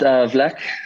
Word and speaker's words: Vlack: And if I'm right, is it pronounced Black, even though it's spelled Vlack? – It's pronounced Vlack. – It is Vlack.